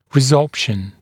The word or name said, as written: resorption